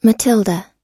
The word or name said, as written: matilda